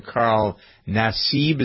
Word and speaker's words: Carl Nassib: And perhaps we'll have the story of Carl Nassib.